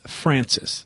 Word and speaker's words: Francois: That's how it's spelled, and people around here pronounce that F-R-A-N-C-O-I-S, Francis. – Yeah, Francis.